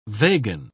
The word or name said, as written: vegan